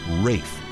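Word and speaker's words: Ralph: And finally, Vaughan Williams' first name is pronounced Rafe. According to wife Ursula, any other pronunciation used to drive him mad.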